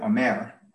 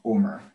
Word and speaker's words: Omer: God gave instructions to ancient Israel to engage in a process which is called counting the Omer, or Omer, if you want to anglicize the pronunciation.